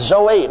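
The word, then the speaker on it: Zoe (ζωή): It is pronounced Zoe. Now I'm not a Greek scholar. But the professor of Greek in Baylor University, in Waco, Texas Baptist Seminary, told me that that's the way you pronounce it, is Zoe.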